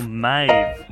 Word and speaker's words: Maeve: M-A-V-E. – Maeve. – Oh, Maeve. No, it says that rhymes with cave. – That's correct. That's right.